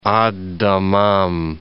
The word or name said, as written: Ad Dammam